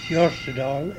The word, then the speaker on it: Hjortedal: A name that I use now, Austin West, E-N, and initial J, Jortedal, H-J-O-R-T-E-D-A-L.